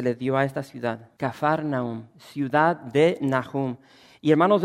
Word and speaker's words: Kafar Nahum: Y hermanos, se nos nombra esta ciudad, la ciudad de Capernaum en sí, la pronunciamos de esa manera, pero es Kafar, así con F, Kafar Nahum. Cuando vas a Israel, como que se te pega un poco el acento de los judíos, escupen mucho. Y es la forma correcta de pronunciar este nombre, Kafar Nahum.